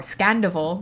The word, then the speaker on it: Scandoval: Or Scandoval, that's how it's pronounced.